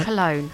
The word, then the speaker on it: Cologne: The team from the Max Planck Institute for Metabolism Research in Colgone... Sorry, Colgon? Is it Colgon or is it Colgone? – Oh, how do you spell it? – Colgone. Colgone, isn't it? – Cologne.